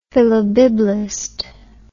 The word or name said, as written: philobiblist